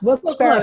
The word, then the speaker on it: Wilkes-Barre: It's pronounced Wilkes-Barre, Scranton area.